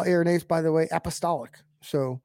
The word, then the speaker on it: Irenaeus: Okay, so we come to a man, and I don't know exactly how to pronounce his name, so you have to forgive me, Irenaeus or Irenaeus. I think it's pronounced Irenaeus.